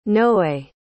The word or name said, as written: noe